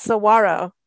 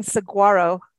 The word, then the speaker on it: saguaro: I said saguaro cactus. – Oh, and yeah. – You had to teach me it's saguaro. You will not be saying saguaro. That is not how you say it.